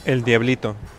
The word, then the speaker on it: El Diablito: How do you say it? – El Diablito.